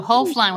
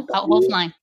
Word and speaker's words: Hoeflein: No, Hoeflein with a long O. So Hoeflein.